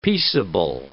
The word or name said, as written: peaceable